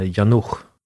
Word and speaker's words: Janouch: Jag har fått för mig, jag tror att jag lärt mig att CH på tjeckiska uttalas som ett tyskt ach. Man borde uttala henne Janouch. Men jag tror inte folk brukar säga så. – Nej, det brukar vara mer ach. Men det ska uttalas lite hårdare, lite mer tyskt.